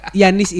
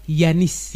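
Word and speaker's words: Giannis: Nggak, tapi Yanis ini ternyata bacanya Yanis. Yanis. Ya pakai Y Pak, Yanis.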